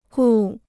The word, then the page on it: 顾: gù